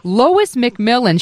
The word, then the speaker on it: Lois MacMillan: The music you're hearing is, of course, from Hamilton, and I'm so excited to have on the air with us Lois McMillan.